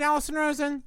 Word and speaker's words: Allison Rosen: Am I saying that right, Allison Rosen? – You're saying it exactly right.